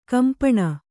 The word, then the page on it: ಖಂಪಣ: khampaṇa